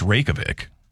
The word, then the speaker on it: Reykjavik: According to a new survey, 45% of travelers admit they'll avoid going to a destination they can't confidently pronounce, like Reykjavik, Iceland. – You know, it's Reykjavik.